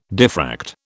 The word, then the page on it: diffract: British English